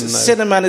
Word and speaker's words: Sinnerman: It's spelled S-I-N-N-E-R-M-A-N. – The Cinnamon. – It rhymes with inner man, which is kind of appropriate.